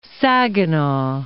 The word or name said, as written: Saginaw